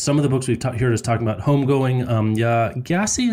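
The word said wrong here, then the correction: Gyasi: Is that how you say it? Jesse. I know I keep messing that up.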